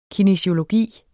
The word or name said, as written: kinesiologi